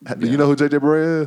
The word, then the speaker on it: Barea: Anyways, J.J. Barea. – I bet ain't how you say his name.